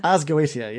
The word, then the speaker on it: Ars Goetia: Ars Goetia, yeah, that's the one.